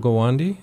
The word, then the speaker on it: Gawande: And this is based off of Dr. Atul Gawande. Am I saying his name right? His book? – Well, I think that's how he pronounces his name.